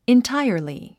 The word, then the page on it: entirely: intáiə r li; インタァイアリィ